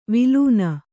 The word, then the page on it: ವಳನ: vaḷana